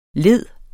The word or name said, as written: li